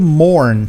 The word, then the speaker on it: mourn: Aliás, perdão, estão dizendo o verbo to mourn. To mourn é enlutar-se ou entrar em luto. Morning, que é quase como a gente também pronuncia manhã, só que é escrito diferente.